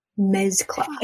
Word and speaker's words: Mezcla: Mezcla. – It's almost like mezcal, yeah. But not mezcal.